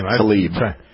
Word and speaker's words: Tlaib: Number 9, Rashida, is it Tlaib? How do you pronounce her name? – Tlaib. – Tlaib. Okay, anyway, so she's in...